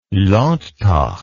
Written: landtag

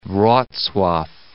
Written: Wroclaw

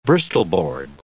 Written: bristol board

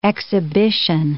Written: exhibition

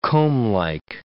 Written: comblike

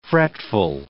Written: fretful

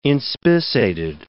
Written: inspissated

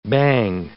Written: bang

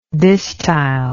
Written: distyle

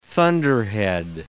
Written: thunderhead

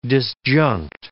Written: disjunct

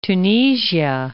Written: Tunisia

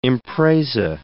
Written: impresa

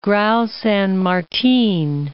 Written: Grau San Martin

